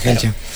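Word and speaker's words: Kejelcha: Bueno, él tenía el récord, ya había tenido el etíope, Yomif Kehelcha. No sé si así se pronuncia. – Sí, Kehelcha.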